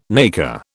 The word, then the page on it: nacre: British English